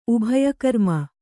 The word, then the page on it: ಉಭಯಕರ್ಮ: Kannada